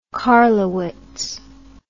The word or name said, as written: carlowitz